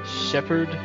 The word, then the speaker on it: Shepherd: Is that like the English word shepherd? – Yes, exactly.